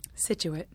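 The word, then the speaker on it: Scituate: Situate.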